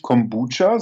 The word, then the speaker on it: Kombucha: Wird es Kombucha ausgesprochen? – Äh, wir sagen mit Absicht Kombucha, so Kombucha. wie die es sagen.